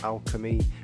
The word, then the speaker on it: Alkemy: I called him DJ Alchemist, it's DJ Alchemy, and it's spelled A-L-K-E-M-Y, but pronounced Alchemy, which is obviously usually spelled with a C-H, but he twisted it up, didn't he, you know, threw a K in there.